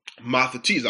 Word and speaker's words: mathetes: Mathetes, I'll spell that for you. M-A-T-H-E-T-E-S.